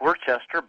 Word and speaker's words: Worcester: It looks like Worcester, but it's Worcester.